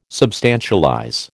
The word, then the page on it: substantialize: American English